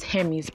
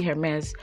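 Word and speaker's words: Hermès: By the time you buy this, let's take for instance, now there's this bag, a lot of people pronounce the designer as Hermes, but the pronunciation is actually Hermes.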